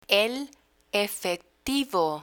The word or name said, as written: el efectivo